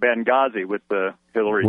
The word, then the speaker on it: Benghazi: Yeah, really the H is silent in Benghazi.